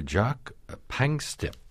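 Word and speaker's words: Jaak Panksepp: You write about Dr. Jacques Pangstip.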